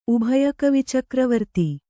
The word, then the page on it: ಉಭಯಕವಿಚಕ್ರವರ್ತಿ: Kannada